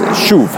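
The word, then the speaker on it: shuv (שוב): Repentance in Hebrew is actually just shuv. That's how you say it.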